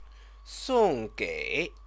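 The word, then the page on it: 送给: sòng gĕi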